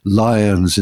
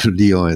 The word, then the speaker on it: Lyon: So he insisted on saying Marseilles instead of Marseille, Lions instead of Lyon, etc.